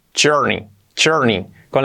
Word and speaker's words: journey: Journey, la pronunciación de journey es journey, con la ch, el sonido de la J, journey.